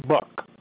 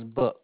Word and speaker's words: Buck: Oh, Michael Buck. B-U-C-K.